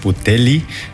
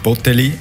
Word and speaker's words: Botelli: Also man sagt Botelli, nicht Botelli. Er kommt nicht aus dem Berner Oberland. Wir haben das auch mit ihm besprochen. Also wer Radio oder Fernsehen kommentiert, Winsley Botelli ist die richtige Aussprache.